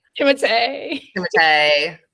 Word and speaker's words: Timothée: Timothée. So funny that that's actually how you meant to pronounce it.